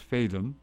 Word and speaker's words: Fadem: I'm not sure that those initials stand for that, but it's B.J. Fatum, F-A-D-E-M, pronounced Fatum.